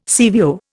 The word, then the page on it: CVO: Indian